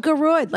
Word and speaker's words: Gearóid: It's spelled Gearóid like a hemorrhoid.